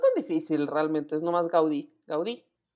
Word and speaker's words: Gaudí: No, es eh, Gaudí, Gaudí, sí, aguda, graves de jula. Yo siempre tengo que hacer aguda, graves de jula, ese vocal, así. – No, sí, es aguda. – Está bien. – La última sílaba, Gaudí.